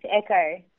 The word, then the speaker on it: ECOH: You've renamed the product to Echo. Is that how you pronounce it? E-C-O-H? – That's correct, Echo.